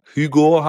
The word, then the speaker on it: Ugo: Und für die Leute, die sich wundern oder fragen, ob der Hugo heißt, also mit H und aus dem Französischen spricht man das nicht. Nee, er heißt einfach Hugo. U-G-O. Aber es gibt auch in Frankreich gibt es auch wirklich welche, die heißen Hugo mit H und dann spricht man es nicht. Die sagen dann auch Hugo. Aber ja, er heißt wirklich ohne H. Hugo.